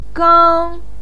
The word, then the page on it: gang1: pinyin